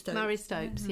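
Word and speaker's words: Marie Stopes: There's Mary Stopes, is that how you pronounce the name? – Mary Stopes, yeah.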